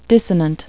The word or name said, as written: dissonant